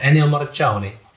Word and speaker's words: Ennio Morricone: So this week, in honor of Ennio Morricone, it's apparently how it's pronounced, my Italian friend told me, I am going to be humming 5 classic movie pieces from soundtracks.